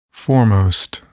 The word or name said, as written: foremost